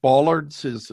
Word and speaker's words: bollards: In this case, your expert talks about bollards, if I'm pronouncing that correctly.